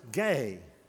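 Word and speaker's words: gay (γῆ): In the original language, the word for world or earth, not world, but earth, is a two-letter word, gamma, eta. It's pronounced gay. Gay, that's the earth.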